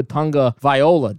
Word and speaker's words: Tagovailoa: Tugavailoa.